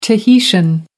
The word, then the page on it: tahitian: American English